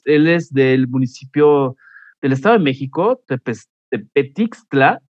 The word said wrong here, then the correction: Tepetlixpa: ¿Cómo se pronuncia? Este, a ver, ayúdame, Jerónimo. ¿Cómo estás? Claro, este, eh, me llamo Jerónimo Farelas y bueno, soy originario del municipio de Tepetlixpa, Estado de Ajá.